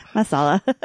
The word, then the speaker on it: masala: So like potato curry or chicken marsala or chicken like marsala. Samosas. Yeah, I said that wrong. It's not marsala. Chicken marsala. It's an Italian dish. Chicken tikka. – Masala.